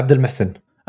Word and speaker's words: Abdulmohsen: Or how do you say it actually? – Yeah, that's actually close. Yeah, Abdulmohsen.